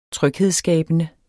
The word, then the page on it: tryghedsskabende: [ ˈtʁœgˌheðsˌsgæːbənə ]; [ ˈtʁœgˌheðsˌsgæˀbənə ]